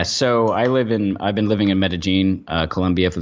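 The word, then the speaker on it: Medellín: Medellin. See, that's how you say it. I was corrected by a Colombian. – Yeah, Medellin. That's the paisa way to say it.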